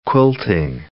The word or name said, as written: quilting